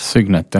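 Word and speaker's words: Cygnet: aga siis tuli välja sihuke Aston Martini äh, Äh, Signet on vist õige hääldatav, et C ja Y-ga alguses. – Signet jah, kõlab nagu Skynet äh, Terminaatori yeah.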